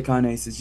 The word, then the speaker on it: kinases: kinases? – Okay, yeah, kinases.